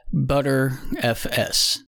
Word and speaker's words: Btrfs: It is pronounced ButterFS.